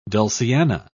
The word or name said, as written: dulciana